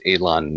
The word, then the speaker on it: Ylan: His name's it's spelled Y-L-A-N, but it's pronounced Elon Knoll.